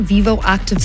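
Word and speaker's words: Vivoactive: A Garmin Vivo Active 6.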